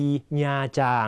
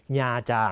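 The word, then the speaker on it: Nha Trang: ญาจางนะครับพ่อของน้องก็ชอบไปญาจางคำนี้ต้องอ่านให้ถูกนะฮะอ่านว่าญาจางน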